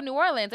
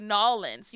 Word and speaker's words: New Orleans: "Oh, New Orleans." And we're like, "Oh yeah, you said it." He's like, "That's it, right?" Like, "Yeah, you said it correctly." She barges over and goes, "No, they say Nolens. You have to say Nolens."